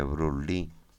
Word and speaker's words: Bruce Lee: Brulí, es decir, que Bruce Lee, se escribe así, aunque se le dice Brulí.